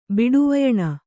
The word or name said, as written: ಬಿಡುವಯಣ